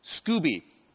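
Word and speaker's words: Skoobe: Sebastian mentioned, how should I pronounce it, Scoba? Scooby, like as in Scooby-Doo.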